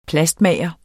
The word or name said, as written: plastmager